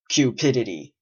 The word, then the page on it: cupidity: British English